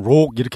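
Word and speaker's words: rock: 영국에서는 록 이렇게 발음하기도 네. 하고 그러니까 미국에서는 락 이렇게 발음합니다만 네.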